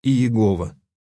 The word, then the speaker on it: Иегова: По-русски оно обычно произносится как «Иегова».